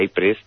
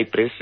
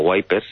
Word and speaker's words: Ypres: Y el nombre de Wipers viene porque, como a los británicos les sonaba la ciudad de Ypres, ellos lo pronunciaban como Wipers, y de ahí viene su nombre.